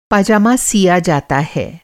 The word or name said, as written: पाजामा